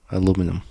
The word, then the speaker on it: aluminium: Aluminium. I think that's how they say it in the UK. Mm. Aluminium. – How do you say it? – Aluminum. – Aluminum.